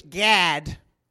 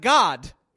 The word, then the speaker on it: Gad: I think you got to say it in English, Gad, because in Hebrew it's actually pronounced God, and that can be confusing. so we'll just say Gad.